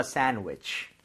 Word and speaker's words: sandwich: Let me make you a sandwich. 정답. Perfect. Okay. SA의 강세를 주고요. 약간 sandwich 이렇게 좀 여유 있게 발음을 해주세요.